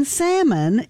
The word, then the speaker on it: salmon: Because the L in salmon is silent.